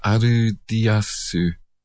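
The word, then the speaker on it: Aldious: Of course, they're written Aldius, but in Japanese that would be pronounced Arudiasu.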